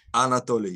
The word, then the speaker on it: Anatoly: Anatoly, yes.